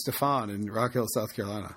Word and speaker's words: Stephon: You like him so much you mispronounce his name, Stephon. – Stephon Gilmore, yeah.